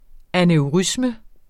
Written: aneurisme

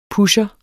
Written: PUSHERE

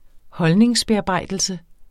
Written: holdningsbearbejdelse

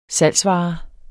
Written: salgsvare

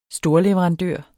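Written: STORLEVERANDØR

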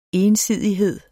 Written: ensidighed

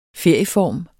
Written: ferieform

